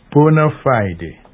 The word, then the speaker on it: bona fide: Bona fide. Note the pronunciation. Bona fide. Not bona fide. Neither is it bona fide.